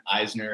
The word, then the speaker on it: Eisner: On Instagram, you can find me just under my name, which is Jason Eisner, and that's spelled E-I-S-N-E-R.